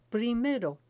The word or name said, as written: primero